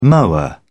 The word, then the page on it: mower: British English